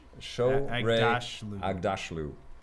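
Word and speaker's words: Shohreh Aghdashloo: Shorey Agdashlu.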